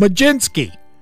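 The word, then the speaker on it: Mlodzinski: Well, Carmen Mulodzinski, who has a name that has no J or G in it, but it's pronounced Majinski.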